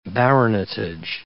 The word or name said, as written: baronetage